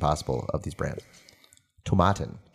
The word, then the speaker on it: Tomatin: Apparently it's Tomatin, like tomatoes.